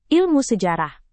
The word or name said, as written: ilmu sejarah